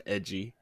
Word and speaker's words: ecchi: It would be pronounced edgy.